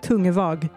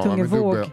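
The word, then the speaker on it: Tungevaag: Mm, DJn heter Tungevåg. Är det som man uttalar det? – Tungevåg borde vara. – Tungevåg.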